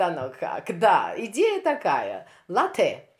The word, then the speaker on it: LATE: Организация, название организации пишется по-английски как L-A-T-E, латте или Late? Как правильно? – Э, я очень рада вас принять у себя дома. Э, наша организация называется «Латте». – Э, «Латте» – это лоскуток.